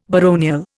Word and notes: baronial: Indian

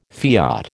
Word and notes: fiat: American English

